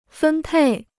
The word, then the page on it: 分配: fēn pèi